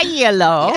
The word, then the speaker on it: Iello: I will be there with the board game company Yellow, which is I-E-L-L-O. – Mm-hmm. – And it took me a second to figure out that that's how you pronounce the name of it. – Yeah, Yellow. – And I was like, oh, it's Yellow.